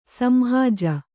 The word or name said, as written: ಸಂಹಾಜ